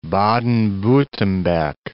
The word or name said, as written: Baden-Wurttemberg